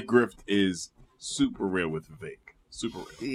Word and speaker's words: Vivek: First of all, it is Vivek, and it rhymes with cake. And that is how you will always know how to pronounce that man's name.